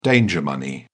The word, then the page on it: danger money: British English